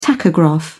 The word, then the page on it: tachograph: British English